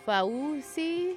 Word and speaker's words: Fauci: Like, when you see it in writing, you're like, Fauci? – Yeah. But it's actually not that hard to just pronounce Fauci.